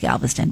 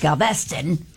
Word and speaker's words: Galveston: That's how they say it. I always thought it was Galveston, but it's Galveston.